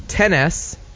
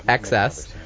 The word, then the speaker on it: XS: So, but either way you say that, it sounds like the word excess, XS, or it sounds like the word tennis, 10S.